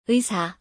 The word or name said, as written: 의사